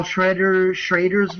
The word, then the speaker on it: Schrader: Part 4, which I saw Paul Schrader's version.